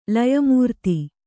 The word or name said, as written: ಲಯಮೂರ್ತಿ